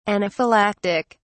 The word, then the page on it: anaphylactic: British English